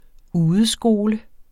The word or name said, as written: udeskole